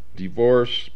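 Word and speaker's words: DeVorss: Divorce Publications, that's spelled D-E-V, as in Victor, O-R-S-S.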